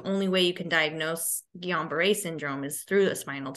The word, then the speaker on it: Guillain-Barré: It's called Guillain-Barré syndrome.